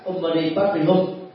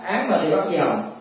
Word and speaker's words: Om Mani Padme Hum: chẳng hạn như là Om Mani Padme Hum, mà người Việt Nam đọc khác Việt đó, thường đọc là Án Mani Padme Hum, rất là xa lạ với từ gốc.